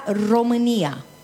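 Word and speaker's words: România: De hecho que Rumanía o Rumanía en rumano se pronuncia Romanía.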